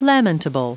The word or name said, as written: lamentable